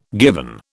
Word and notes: given: British English

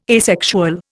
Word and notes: asexual: Indian

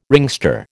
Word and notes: ringster: American English

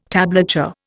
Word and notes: tablature: Indian

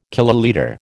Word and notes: kilolitre: American English

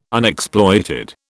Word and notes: Unexploited: British English

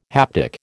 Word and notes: haptic: American English

